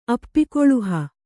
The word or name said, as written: ಅಪ್ಪಿಕೊಳುಹ